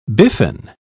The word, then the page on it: biffin: British English